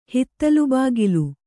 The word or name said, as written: ಹಿತ್ತಿಲುಬಾಗಿಲು